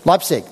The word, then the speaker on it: Leipzig: Amongst the churches we went to was a church in this place, Leipzig. Is that how you say it? Leipzig. Okay.